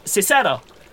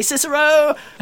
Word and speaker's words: Cicero: Cicero. Cicero. Ah! Who's asking? – I think it's pronounced Kikero, actually, yeah.